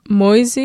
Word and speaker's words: Moise: Moise, is how I pronounce it, M-O-I-S-E, Moise.